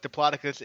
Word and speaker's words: Diplodocus: Well, I looked it up. I looked it up. It's apparently both ways are correct. But Diplodocus is the preferred way to say it. So they're right, and I was wrong.